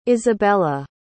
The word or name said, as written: isabela